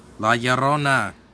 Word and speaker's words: La Llorona: And I always butcher this name. La Llorona. – He didn't butcher it. Yay! He said it like how my great-grandma would say it.